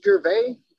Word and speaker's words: Gervais: It's Gervais.